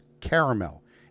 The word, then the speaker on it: caramel: And he goes, caramel. I go, no. It's caramel. If it wanted to be pronounced caramel, it'd be spelt C-A-R-M-E-L. It is not. You put an extra A and it's caramel.